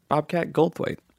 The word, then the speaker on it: Goldthwait: Bobcat Goldthwait.